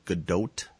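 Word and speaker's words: Gadot: I think they settled on the pronunciation of Godot, and when they were announcing her, I think that's more accurate, because you're actually supposed to pronounce the T, because she's not French, she's Israeli. Only French people are pretentious enough to say the T is silent.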